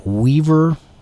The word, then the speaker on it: Wever: Coming to you this evening from Weaver, Iowa. I think that's how it's pronounced, W-E-V-E-R. So it's kind of an unusual spelling for the word Weaver, but it's probably named after someone, W-E-V-E-R.